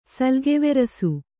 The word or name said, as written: ಸಲ್ಗೆವೆರಸು